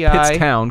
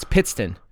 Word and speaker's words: Pittston: So it's not Pittstown because there's a Pottstown. – Right. It's Pittston.